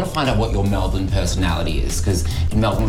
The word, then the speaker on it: Melbourne: Let's go around and the pronunciation of Melbourne. – Melbourne. – Nailed it. Yay. Round of applause. Yeah. We like to throw away our vowels in Australia.